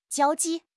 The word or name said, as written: jiao1 ji4